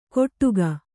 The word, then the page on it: ಕೊಟ್ಟುಗ: koṭṭuga